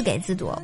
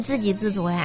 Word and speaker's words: 自给自足: 像上期节目我说了一个字给字读，我知道那是自给自足呀。小伙伴还来纠正我的读音，我特别感谢哈。